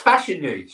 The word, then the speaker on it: Fashanu: It's Fashanu. Surely it's like big old John Fashanu. – I've called him Fashanu.